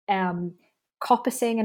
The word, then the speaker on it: coppicing: Coppicing and pollarding. – Perfect pronunciations.